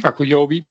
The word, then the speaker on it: Akuyobi: En op de back hebben we Floranus en die andere jongen ben ik even Ayukobi, zeg ik dat goed? Die naam? – Akuyobi.